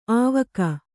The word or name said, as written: ಆವಕ್